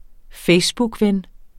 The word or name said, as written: facebookven